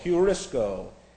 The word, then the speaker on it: heurisko: That's H-E-U. That's a rough breather. H-E-U. And then we have R-I-S-K-O, heurisko. H-E-U-R-I-S-K, long O on the end.